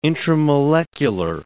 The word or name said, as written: intramolecular